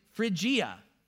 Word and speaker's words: Phrygia: This one is Phrygia, okay? So Phrygia and Galatia. You know Galatia. Paul and his companions traveled throughout the region. These aren't cities. Phrygia and Galatia are regions.